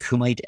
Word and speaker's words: Kumite: So this is Kumite. I believe that's how you say it. Kumite NFT. – Sounds right.